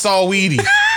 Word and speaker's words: Saweetie: How you say her name? – Saweetie. Sweetie, I think. Just regular Sweetie. – It's Saweetie.